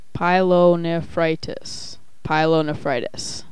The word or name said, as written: pyelonephritis